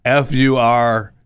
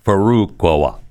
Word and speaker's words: Furukawa: No, that's not how he pronounces it. Furukawa. Furukawa.